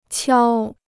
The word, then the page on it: 敲: qiāo